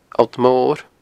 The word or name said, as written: Allt Mor